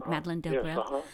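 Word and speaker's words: Delbrêl: One of the quotes here that I liked very much, Madeleine Delbrel, is that how we say her name? Madeleine Delbrel? – Yes, uh-huh.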